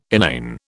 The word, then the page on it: inane: British English